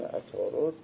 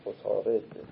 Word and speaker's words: عطارد: این عطارد رو مثل در فارسی عربی تلفظش دو جوره، دو در فارسی میگن عطارد، در عربی میگن عطارد، یعنی به دو نحوه تلفظ میشه، ولی هر دوش کلمش یکی اما دو تلفظ مختلف، عطارد و عطارد.